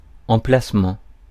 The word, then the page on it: emplacement: [ɑ̃.plas.mɑ̃]; France